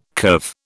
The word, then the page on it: curve: British English